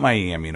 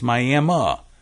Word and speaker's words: Miami: We have a town that looks like it should be Miami in Oklahoma, but it's not Miami in Oklahoma. It's Miami in Oklahoma. But in Ohio, it's Miami.